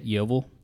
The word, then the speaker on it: Yeovil: Yeovil? – Yeah.